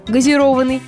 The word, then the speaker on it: газированный: Прилагательное значит только с ударением на «о» – газированный.